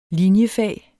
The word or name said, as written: linjefag